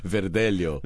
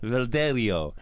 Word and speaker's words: Verdelho: How do you say it? – Verdelho. – Verdelho. Yeah, kind of like there's that onion. The Vidalia.